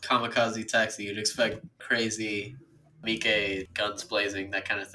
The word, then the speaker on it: Miike: Is that really how you pronounce Mike's name? – No, it's Miike.